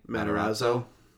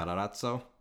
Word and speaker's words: Matarazzo: Matarazzo. Yeah. – Matarazzo.